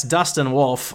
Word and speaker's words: Dustin Wolf: Weston Dolph, excuse me, that's Dustin Wolf, was named the AHL Goalie of the Month.